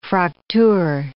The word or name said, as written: Fraktur